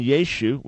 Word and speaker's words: Yeshu: The meaning of Messianic Jews derives from faith in Yeshu, which is the traditional Jewish way of pronouncing his name,